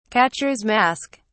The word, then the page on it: catcher's mask: British English